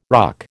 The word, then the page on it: RUC: American English